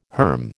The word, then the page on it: herm: American English